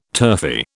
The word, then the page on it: turfy: British English